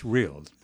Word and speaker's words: Rhyl: But it's pronounced Rhyl. That part I was instructed on how to say it.